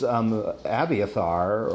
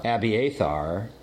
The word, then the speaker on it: Abiathar: So is Abiathar, or Abiathar, or however you want to pronounce his name. – Abiathar.